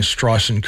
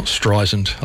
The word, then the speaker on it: Streisand: Those of you who are pedants know, I'm pronouncing Streisand correctly now. It's not Streisand.